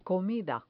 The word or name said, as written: comida